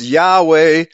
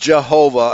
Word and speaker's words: Yahweh: So we have been surnamed by his name Yah, and it's pronounced Yahweh. It's not pronounced Jehovah. I just put a link in the chat room saying that Jehovah is a confabulation. It's not the real pronunciation.